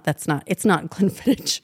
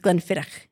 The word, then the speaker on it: Glenfiddich: it's not Glenfiddich. Don't ever say that again. It's Glenfiddich.